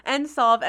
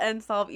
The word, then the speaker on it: NSLVE: So NSOLV, as we call it, it's the National Study of Learning, Voting, and Engagement. And we call it NSOLV, even though it's spelled NSLVE.